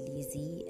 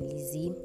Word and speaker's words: Leezee: My name is Elizabeth, but you can call me Lizzie, and spelled with L-E-E-Z-E-E. Yes, I can be dramatic sometimes, but yeah, that's how I spell my own Lizzie.